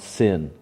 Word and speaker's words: Zin: I think that word Zin there is pronounced sin in the Hebrew.